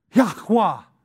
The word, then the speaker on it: Yahweh: Yahweh is how the Hebrews pronounce it.